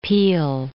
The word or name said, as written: Peale